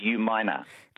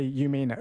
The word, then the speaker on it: Umina: And also, Umina, like you said before, is U-minor. – U-minor, that's right. But it had been spelt out phonetically by Stuart. So U-minor, but people sometimes say U-mina, Umina.